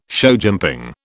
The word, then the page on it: showjumping: British English